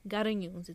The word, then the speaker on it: Garanhuns: So yeah, we're talking today about the um Garignons cannibals. Is it no? I said the name wrong. Garanhuns. It's with an A.